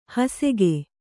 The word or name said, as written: ಹುಸಿಕ